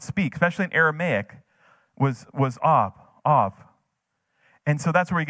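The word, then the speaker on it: Av (אב): The Hebrew for father, it's pronounced, it's A, the letter's A-B, but Av.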